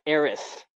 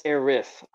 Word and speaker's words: Aerith: There are multiple pronunciations. In the original pronunciation is Aerith with a TH in the East. And the West, for some reason, is Aerith with an IS.